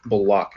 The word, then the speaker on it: Bullock: I will also say that, man, Bullock is Bullock.